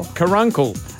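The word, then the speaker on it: caruncle: Lacrimical carungles. – That is not how you say it, I'm sure. – Carunkle. – What? – A lacrimal carunkle.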